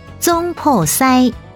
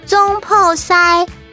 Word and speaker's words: 厨师: 厨师台语念作宗破塞，宗破塞。客语念作厨师，厨师。 – 厨师台语念作中破塞，中破塞。